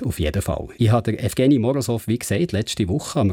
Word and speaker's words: Evgeny Morozov: But uh, the proper Russian version would be Evgeny Morozov. Evgeny Morozov.